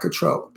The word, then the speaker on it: Cattrall: I think her name is pronounced Cattrall. – Cattrall? – Yeah, not Cattrall. People say Cattrall, but it's Cattrall.